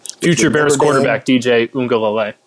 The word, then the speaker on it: Uiagalelei: Is that really how you pronounce it? – Uiagalelei. I'm in broadcast for a living. I had to, no, you're wrong.